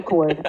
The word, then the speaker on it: Cord: I don't think it's Chord Overstreet. I'm pretty sure it's Cord Overstreet. – Okay. – If his name was Chord, that's very unfortunate. – Let's call him Cord.